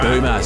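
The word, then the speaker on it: Böhmer: Moving from darkness Been saying Ben Bohmer because that's how it's spelled, but then our friend Duncan from Injuna Beach said it's pronounced like schooner, so there you go, Ben right. Bohmer, so take it as you please, ladies and gentlemen.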